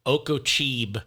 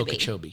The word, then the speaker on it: Okeechobee: And when I was filming Burn Notice down there, I said Okeechobee, which made the whole crew. – What is it supposed to Okeechobee. – Be? Okeechobee.